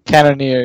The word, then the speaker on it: Cannonier: I don't know how to pronounce his last name. – Cannonier, yeah. – Yeah, there you go.